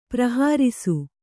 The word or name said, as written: ಪ್ರಹರ್ಷ